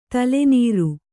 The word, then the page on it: ತಲೆನೀರು: tale nīru; kannada